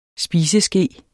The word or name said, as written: spiseske